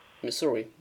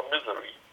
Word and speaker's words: Missouri: Habe ich das jetzt richtig ausgesprochen? Missouri, ja, habe ich wieder falsch ausgesprochen. – Ja, du sagst immer Misery.